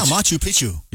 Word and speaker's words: Machu Picchu: It reaches all seven continents, and you get to see places like the Taj Mahal, the Great Wall of China, the Colosseum, Machu Picchu. I think that's how you say it. – Oh, yeah, Machu Picchu.